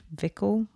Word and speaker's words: Vico: It's, I hope I'm pronouncing it right, Vico, V-I-C-O, or Vico.